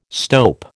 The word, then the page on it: stope: American English